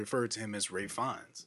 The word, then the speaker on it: Ralph Fiennes: Rafe? Is that how it's pronounced? Okay. Rafe Fiennes. Thank you. – Even though it's spelled Ralph.